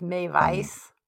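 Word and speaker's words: Meiwes: Oh, maybe it's Mewis. – Probably actually Mewis, since they pronounce their W's. – As V's.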